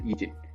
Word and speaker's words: Lee Jin: 呃，那首先第一位成员 E 景，他的这个拼法是 Lee Jin，OK，L E E J I N。那因为他的这个姓是李嘛，就是在韩国是念 E 的，所以用韩文发音的话就是 E 景，但是他的拼法是 Lee Jin，OK。